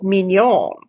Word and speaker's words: Mignon: It's not Minion, but it's M-I-G-N-O-N. – Oh, Mignon. – Mignon. Thank you. – Like, I think that's like filet mignon.